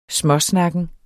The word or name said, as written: småsnakken